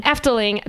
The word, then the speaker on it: Efteling: Efteling.